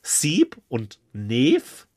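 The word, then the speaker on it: Seeb & Neev: Und es ist von, ich hoffe, ich spreche die aus, Sieb und Neef. Spricht man denn wirklich so aus? – Ja.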